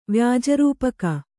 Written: ವ್ಯಾಜರೂಪಕ

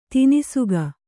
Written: ತನಿಸೊಕ್ಕು

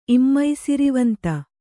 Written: ಇಮ್ಮೈಸಿರಿವಂತ

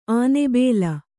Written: ಆನೆಬೇಲ

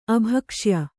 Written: ಅಭಕ್ಷ್ಯ

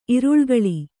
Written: ಇರುಳುಗಳ್ಳ

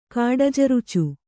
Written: ಕಾಡಜರುಚು

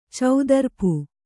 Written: ಚೌದರ್ಪು

